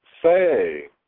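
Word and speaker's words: fay (פ): But the pay is a double letter. It has two sounds. The B sound and the F sound. If you were spelling the name Philip, P-H-I-L-L-I-P, you'd spell it with a pay, but pronounce it fay.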